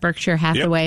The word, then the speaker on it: Berkshire Hathaway: Is that how you pronounce that? Berkshire Hathaway? – Yep.